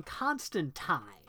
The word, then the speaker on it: Constantine: So for those of you playing along at home, at some point, Alan Moore mentions in an interview that the character's name is pronounced John Constantine.